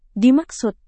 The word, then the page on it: dimaksud: indonesian